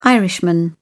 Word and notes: irishman: British English

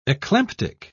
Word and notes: eclamptic: British English

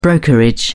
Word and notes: brokerage: British English

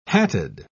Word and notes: hatted: British English

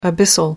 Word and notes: abyssal: American English

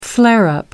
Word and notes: flare-up: American English

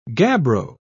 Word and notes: gabbro: British English

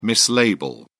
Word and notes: mislabel: British English

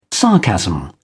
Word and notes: sarcasm: British English

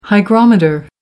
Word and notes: hygrometer: American English

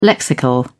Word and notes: lexical: British English

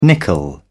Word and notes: nickel: British English

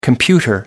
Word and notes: computer: American English

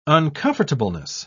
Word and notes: uncomfortableness: British English

